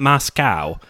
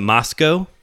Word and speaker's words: Moscow: This is where all of it got started, was in a town in Idaho named Moscow, not Moscow, but Moscow. You have to say it correctly.